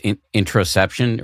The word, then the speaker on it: interoception: And it also gives you, as you write in the book, I always mispronounce this word, but I think it's interoception.